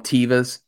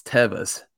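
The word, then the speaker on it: Teva: But yeah, like I just found out the other day that so you guys, I wear these sandals, but the, you know, I call them Tevas, right? Well, I just found out the other day that's not even the right way to pronounce it. It's Tevas.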